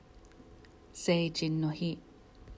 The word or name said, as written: Seijin no Hi